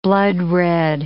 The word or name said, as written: bloodred